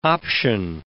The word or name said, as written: option